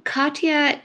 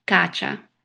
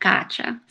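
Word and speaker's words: Kátia: There are two different ways to pronounce it, and both are acceptable. So Katya is a way that a lot of people pronounce it in America, and it's more the Russian way of pronouncing it, like Katya. But in Portuguese, like Brazilian Portuguese, it's pronounced gacha.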